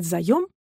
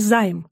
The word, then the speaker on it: заем: Если вы хотите сделать заем, то запомните. В именительном и винительном падежах это слово следует произносить именно так – «заем», но никак не «займ», что часто можно услышать.